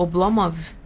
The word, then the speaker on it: Oblomov: É, e eu, eu, recentemente a gente está republicando o Oblomov.